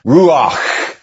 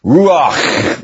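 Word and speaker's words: ruach (רוח): Remember, you read Hebrew from right to left, and you pronounce that Hebrew phrase, a Hebrew term, ruach. Ruach, with a guttural ch on the end of it. Okay, it even sounds breathy, doesn't it? Ruach.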